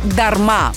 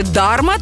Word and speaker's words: дарма: Головне – наголос на останньому складі – дарма. Бо якщо сказати «дарма», то звучить ніби новий нік у геймерів.